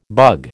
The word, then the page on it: bug: American English